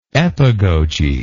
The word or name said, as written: epagoge